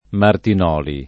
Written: Martinoli

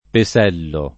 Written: Pesello

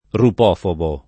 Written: rupofobo

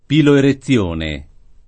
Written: piloerezione

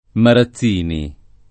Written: Marazzini

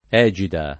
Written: egida

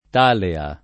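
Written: talea

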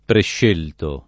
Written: prescelto

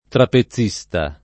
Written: trapezista

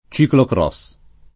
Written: ciclocross

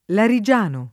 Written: larigiano

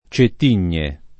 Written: Cettigne

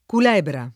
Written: Culebra

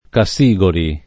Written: Cassigoli

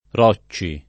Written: Rocci

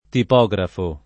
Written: tipografo

